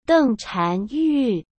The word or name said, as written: dengchanyu